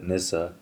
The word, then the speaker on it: Nisse: I'm going to say Nessa now. That was stuck in my head. – I saw that coming. – I was like, it's spelled the same. – They pronounced it Nissa.